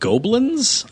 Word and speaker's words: Gobelins: Five, I believe this is pronounced Goblins. It's spelled G-O-B-E-L-I-N-S.